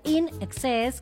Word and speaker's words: INXS: Bueno, in excess significa, efectivamente se pronuncia in excess,